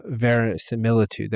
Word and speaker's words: verisimilitude: Any other advice, things to do or not to do when it comes down to combat realism and verisimilitude? There we go. I said it wrong, I think, even at the beginning of this when I told you, I said it wrong. Verisimilitude. There we go.